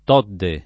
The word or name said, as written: Todde